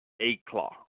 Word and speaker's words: éclat: Let's see, today's word of the day is ACLAT, which is spelled E-C-L-A-T, ACLAT.